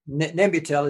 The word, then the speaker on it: Nembutal: At Dignitas in Switzerland, I believe, that they're drinking, is it, how do you pronounce it, Nembutal? – Nembutal is, it's the holy grail of end-of-life drugs, and it's been around since the 1950s.